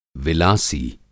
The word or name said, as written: विलासी